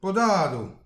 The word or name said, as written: podadou